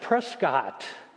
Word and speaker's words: Prescott: And then someone mentioned Prescott, and then we thought, they don't even know how to pronounce the cities out there. It's Prescott.